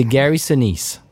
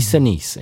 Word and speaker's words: Gary Sinise: Alors il, prononce Gary Sinise, bon, bien évidemment, si on n'entend pas prononcé par un Américain, on peut pas le deviner, mais c'est Gary Sinise. Voilà, ça se dit Sinise et non pas Sinise.